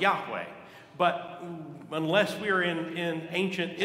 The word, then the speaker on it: Yahweh: In Hebrew, it's believed to be pronounced Yahweh.